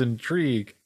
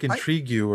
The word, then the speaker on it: intrigue: Intrigue. Intrigue. The word is intrigue.